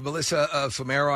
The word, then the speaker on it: Melissa Fumero: You have Melissa Fumero, I believe her name is pronounced.